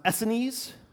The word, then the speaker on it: Essenes: That bottom left-hand corner, that's pronounced Essenes.